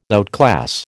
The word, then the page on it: outclass: American English